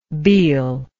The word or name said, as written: Bhil